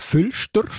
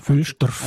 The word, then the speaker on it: Füllinsdorf: Füllinsdorf spricht man aber gar nicht so aus, gell? Wenn man Nein, in Basel bin jetzt, sagen wir Füllstorf.